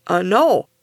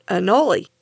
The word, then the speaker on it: anole: Paul from the awesome podcast Varmints messaged me to point out that the word spelled A-N-O-L-E is pronounced annul, not annulli. I'd looked it up before I recorded, so that confused me. So I looked it up again, and it turns out that both pronunciations are used in different places, and both are correct.